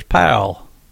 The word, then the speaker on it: Powell: And that's how we pronounce it around here, is Powell.